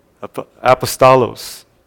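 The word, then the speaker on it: apostolos (ἀπόστολος): The actual Greek word is pronounced apostolos. Apostolos.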